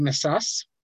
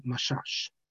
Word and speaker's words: Misas: So I wrote to Rav David Misas. Often people in Israel pronounce it Mashash. It can come in Israel, but the name is really Misas. That's how it was pronounced in Morocco.